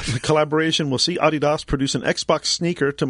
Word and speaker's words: Adidas: Yes, but the correct pronunciation, as we've learned every year when we're in Germany for IFA, is Adidas. – Adidas. – Because it's Adi and DOS, two brothers.